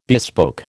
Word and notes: bespoke: American English

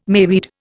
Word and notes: mayweed: Indian

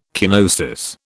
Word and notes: kenosis: British English

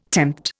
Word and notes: tempt: Indian

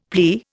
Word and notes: plea: Indian

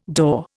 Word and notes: daw: Indian

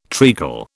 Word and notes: treacle: British English

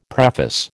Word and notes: preface: American English

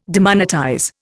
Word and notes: demonetize: Indian

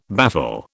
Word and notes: baffle: British English

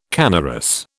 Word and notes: canorous: British English

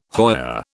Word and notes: hoya: British English

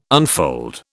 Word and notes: unfold: British English

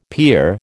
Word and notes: pier: American English

